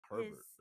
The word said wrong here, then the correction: Hebert: No, but there's, it's Hebert. – H-E-B. – Hebert.